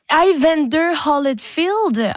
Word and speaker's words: Evander Holyfield: Evander Holyfield.